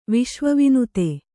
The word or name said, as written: ವಿಶ್ವವಿನುತೆ